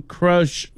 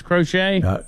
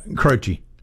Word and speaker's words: Croce: Jim Croce. What do we say his name was? Crochet? – Croce. – Croce.